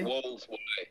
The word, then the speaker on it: Wolds: I'm going to butcher this word, Wolds? Is it Worlds or Wolds? Wolds Way? – Wolds Way, yeah, man.